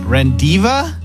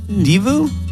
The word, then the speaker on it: rendezvous: R-E-N-D-E-Z-V-O-U-S. R-E-N-D-E-Z-V-O-U-S. – Rendiva? Divu?